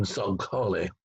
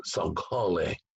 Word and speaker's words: Saghale: Remember that G in Saghale is like the K in Tacoma. It's deep guttural. Not a G of soft G, but a deep guttural, Saghale.